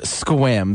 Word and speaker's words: Sequim: All righty, we're going to head up north, and it's off the coast of Seattle, and actually most people see it on the map as Sequim, but it's just pronounced Squim.